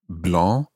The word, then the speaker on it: blanc: Blanc is spelt B-L-A-N-C. Blanc. And if we were to add an E there, it would become blance. And that just doesn't sound right.